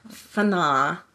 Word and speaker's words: Fanaa: I actually know how to say it. Fana.